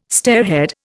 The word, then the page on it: stairhead: Indian